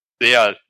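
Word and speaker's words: Zexal: Seal, weil es kommt vom englischen Ziel Eifer. – Ah. – Also Z-E-A-L. – Und warum ist da ein wahrscheinlich X drin? bestätigen können. Es ist stumm.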